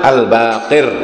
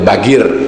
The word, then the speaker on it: Al-Baqir: Katanya ada satu riwayat berasal dari Muhammad Al-Baqir. Kalau orang Indonesia menyebutnya Bagir, ya. Bagir karena pengaruh dari Hadromi dari Yaman. Hadramaut mengatakan bahwa qaf itu dibaca go, ya.